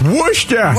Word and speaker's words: Worcester: Is it Wooster? – Ryan. W O R. Is that? Uh, but Worcester. Worcester. Yeah.